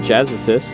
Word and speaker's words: Jazzist: The Jagged Jazzist. Jazzists. I added one syllable there.